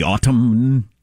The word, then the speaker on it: autumn: I was out last night, a big, bright, giant moon to welcome the uh, the autumn. I pronounce the M and the N in autumn.